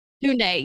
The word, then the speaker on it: Tunde: Tunde. – Yeah. – Tunde.